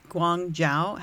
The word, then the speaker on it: Guangzhou: G-U-A-N. – Come on, keep going. – G-U-A-N... G-O-U? – No. G-U-A-N-G-Z-H-O-U. Guangzhou. – Guangzhou. – No, it's Guangzhou. – Guangzhou. – I've listened to lots of pronunciation for this, okay? – Guangzhou. Guangzhou. Okay. – You don't pronounce the G. It's the second G.